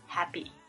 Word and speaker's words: happy: 其实这个 happy, 它这个 pp 的这个声音呢，其实比较接近 b 这个声音。 Happiness, happy.